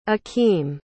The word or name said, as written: akeem